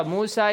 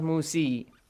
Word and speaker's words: Musa: So, Musa is not pronounced Musi. – Musi? – Musa.